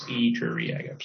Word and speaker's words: Iturriaga: It's Spanish, so it's easy to pronounce because the I's, every letter sounds the same, so it's the I's are just pronounced E's, so it's Iturriaga, so perfect.